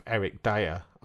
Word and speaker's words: Eric Dier: Some words that rhyme with Eric Dier are tire, hire, fire, and buyer.